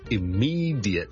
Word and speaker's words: immediate: 今日同大家讲一个好常用嘅一个字嘅英文发音，就系、是、咧 immediate, I M M E D I A T E，立刻立即。咁啊，有个听众问萧叔。 D 日咧應該點樣讀得好啲？正常咧，我哋如果係好快咁讀咧，我哋可能會讀成 J 嘅啫，J J J，好似 J I T 咁嚟讀，J J J，或者用一個好弱嘅 schwa 音，J J J 或者 J 都得，immediate immediate immediate，咁加埋個 L Y 咧，我就會聽到咧，immediately immediately immediately。